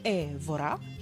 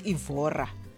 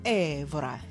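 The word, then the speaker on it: Évora: It's Cesaria Evora, he says, not Evora, like we usually say there in the townships. Cesaria Evora.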